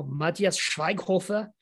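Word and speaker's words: Matthias Schweighöfer: I will pronounce it in my best German possible, Matthias Schweighofer.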